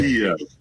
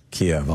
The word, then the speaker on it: Kyiv: it is not as easy as simply saying Kyiv. It's not a single-syllable city. It is Kyiv. – So, okay. – Kyiv. – Kyiv.